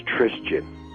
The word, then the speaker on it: Trisjin: It's Trisjin. T-R-I-S-J-I-N. I made it up myself. – Okay, and your older son? – Is Justin. – Justin, Joseph, and... – Trisjin. – Trisjin. – Yeah, T-R-I-S-J-I-N.